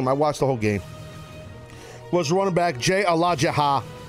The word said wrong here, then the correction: Ajayi: I say his name, Alaha. – I think it's Ajayi? – Yes. – I think that's how it's pronounced.